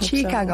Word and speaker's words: Chiikago: I hope Chicago. – So. – Am I saying it right? – Yes. Okay. I think people say it differently. Like, Chicago, it's fine. Both ways work.